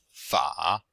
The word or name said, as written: fa3